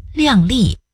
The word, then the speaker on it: 量: 你再看看，当表示估计、衡量的量摆在一个词的前头时，却读作量了。譬如量力，量力而为，不自量力，量入为出，量体裁衣。